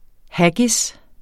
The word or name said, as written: haggis